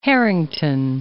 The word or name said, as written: Harrington